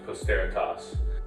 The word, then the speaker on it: posteritas: So the name I chose for my wine is a Latin word that means posterity, and it's pronounced posteritas.